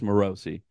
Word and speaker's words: Morosi: Nick told me it's Morosi.